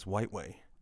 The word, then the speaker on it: Whiteway: Now, let's get to your last name because this is, is it pronounced Whiteway or Whitway? There's been a lot of debate over this in our friendship circles. – What? Anybody who has seen how it's spelled knows it's Whiteway.